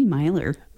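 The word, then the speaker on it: Meylor: But it's M E Y L O R, Correct. but it's pronounced Myler It is. – with an M, like M Y.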